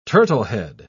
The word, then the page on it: turtlehead: British English